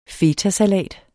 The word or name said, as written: fetasalat